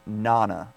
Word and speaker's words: Nana: I believe this is how you pronounce it, but it's going to be Nana. So, N-A-N-A. So, I can see some people saying Nana, but the pronunciation, at least right now, unless the National Hurricane Center changes it on us again, it says the pronunciation is capital N, capital A, dash, lowercase N, lowercase A.